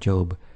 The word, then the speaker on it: Job: Looking ahead, in our next lesson we will visit a character called Job, pronounced with a long vowel.